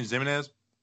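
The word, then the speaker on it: Ximines: By the way, people got mad at us for saying Ximenez. They're like, can you stop pronouncing the X? Well, I was like, it's kind of the X-Man. But I appreciate you telling us that we were pronouncing it wrong because if you didn't, I would have pronounced it wrong till the end of time.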